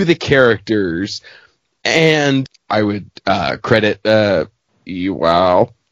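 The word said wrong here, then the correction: Iwao: I never pronounce it right. Ewo.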